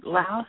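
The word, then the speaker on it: Louth: My place of birth was in Laos, a place east coast of England.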